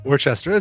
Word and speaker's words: Worcester: Worcester. It's a common thing, but it's you basically pronounce the first five letters as worse, and then the last four letters as stir.